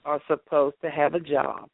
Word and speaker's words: Job: And if you are thinking now, well, I saw a job in the Bible. No, that's a book in the Bible, and that's actually Job. It's spelled like job, but that's Job.